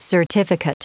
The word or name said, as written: certificate